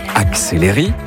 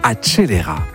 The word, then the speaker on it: accelera: Lio dit « acceleri » alors que le mot italien doit plutôt sonner « accelera ».